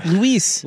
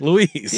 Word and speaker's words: Luis: Luigi? – Yeah, his name's, what did I say his name was? Luis. – Luis. – Yeah. – Good job, Luis.